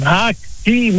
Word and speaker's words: Haakie: Haakie, moet ik zeggen, zo moet ik het uitspreken, uit Haaksbergen.